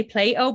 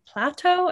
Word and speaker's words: Plato: Sorry, I always get in trouble for saying this wrong because my Irish accent. So I say Plato, but it's really Plato, I think. – No, I think it is Plato.